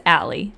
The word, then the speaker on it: Aly: Sometimes they call me Baylee. Sometimes they call me Haley. But it's Allie.